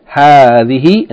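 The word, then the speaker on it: hadhihi (هذه): And hadhihi is pronounced as though the first syllable is long, hadhihi, in the same way as hadha is pronounced hadha, as though there's an aleph, even though it is not written.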